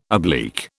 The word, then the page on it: oblique: British English